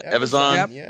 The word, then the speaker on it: Evazan: Speaking of which, how awesome was it to see like Ponda Baba and Dr. – how do you pronounce it? Evazan? Evazan? – Yep. That works.